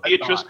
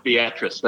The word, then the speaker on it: Beatrice: It looks like Beatrice, but it's pronounced Beatrice, as the locals would call it.